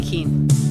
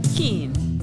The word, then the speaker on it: Kuehn: How do you say your last name? – Whynde Kuehn. – Kuehn? – Kuehn.